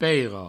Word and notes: peira: peiro; provencal

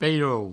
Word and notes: pairou: peirou

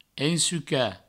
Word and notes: ensucar: ensuca; provençal

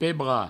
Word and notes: pebrar: pebra; provencal

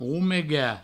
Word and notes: romegar: roumega; Provençal

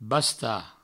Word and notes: bastar: Provençal; basta

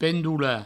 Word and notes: pendolar: pendoula; provencal

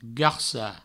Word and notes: garcar: garca

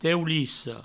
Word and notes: teulissa: teulisso

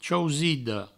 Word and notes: chausida: chausido; provençal

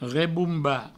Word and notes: rebombar: reboumba

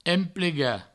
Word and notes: emplegar: emplega

